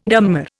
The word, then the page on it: dammar: Indian